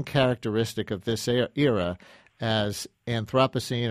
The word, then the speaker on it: Anthropocene: we're living in the Anthropocene epoch. Did I say it right? – Yeah, you know, I've heard it Anthropocene and Anthropocene. Either way, I'd say Anthropocene.